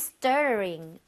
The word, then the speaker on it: Stuttering: Why are you stuttering? Are you feeling guilty? – Alright. Stuttering 발음 쉽지 않죠? – Stuttering. – Yeah. – Stuttering. 어려워요. – 얘가 flap인데 다시 또 R 사운드라서 yeah, 쉽지 않습니다.